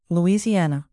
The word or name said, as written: louisiana